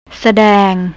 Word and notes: sadang: thai

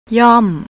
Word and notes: yom: thai

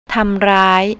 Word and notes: tamrai: thai

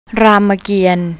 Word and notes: rammakian: thai

